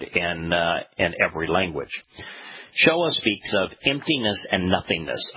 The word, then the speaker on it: Shoah: The most likely pronunciation based upon the letters is Shoah. But with decreasing reliability, it could be pronounced Shoei, Shua, or Shui.